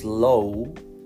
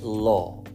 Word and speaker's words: law: Ojo a la pronunciación de ley, because if you pronounce low, se está confundiendo con low como bajo volumen, low volume. So you must say law, con una L y una O, law.